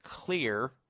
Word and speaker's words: Xlear: It's called Clear Nasal Spray, X-L-E-A-R, Clear Nasal Spray. And this has in it xylitol. That's why it's spelled X-L-E-A-R. The X is for xylitol.